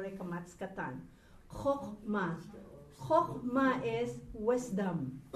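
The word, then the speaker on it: kochma (חכמה): This is not kachma. This is kochma. This is the O sound.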